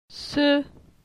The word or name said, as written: Se